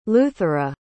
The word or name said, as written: luthera